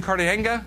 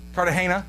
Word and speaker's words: Cartagena: If he's called you to Cartagena, I can't even say it right. Cartagena? Right, I know, you're right. I know. Again, thanks. he's, co- he's correcting me constantly. No, no, no, man, that's not it whatever. He's right again.